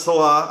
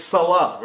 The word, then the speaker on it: Salah: It's Salah, right? Not Salah. – It's Mohamed Salah. So Salah, you could say Mohamed Salah. – But not Salah. – No, Mo Salah. – Not Mo Salah. – No, Mo Salah. – Not chicken Mo Salah. No. That's not the right pronunciation.